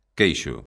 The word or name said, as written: queixo